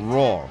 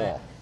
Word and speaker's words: Roar: How do you pronounce it? – That was Roar. Perfect. – That was perfect. – Roar. – Yeah. – Not Excellent. – Raw. – No. – R-O-A-R. – R-O-A-R. – Okay. R-O-A-R.